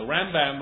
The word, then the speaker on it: Rambam: The Rambam, Rabbeinu Moshe ben Maimon, usually is the emphasis on the beginning of the word.